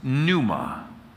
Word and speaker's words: pneuma: It's pronounced pneuma. Give me a pneuma. Yeah. It's not pneuma, right? It's pneuma.